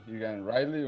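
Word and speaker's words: Egan-Riley: Y además en la lateral derecha, eh, es Igan Riley, CJ, lo estoy pronunciando bien. ¿Qué nos dices del lateral derecho que arrancó el partido? – Sí, sí, fue la sorpresa, CJ Egan Riley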